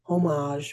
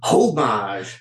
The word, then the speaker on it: homage: The New York Post said, "The romantic, nostalgic single is an appropriate homage for Swift." – I'm so proud of you for saying that correctly. – Homage. – Homage.